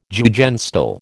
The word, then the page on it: Jugendstil: American English